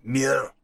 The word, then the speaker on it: Muir: I said to her, is it Muir? I said, how do you pronounce it? She went, Muir.